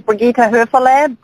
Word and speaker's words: Brigitta Hoeferle: So my name is Brigitta Hoeferle, B-R-I-G-I-T-T-A-H-O-E-F-E-R-L-E. That's how you spell it, and you sound it out just like you spell it. So Brigitta Hoeferle, pretty straightforward.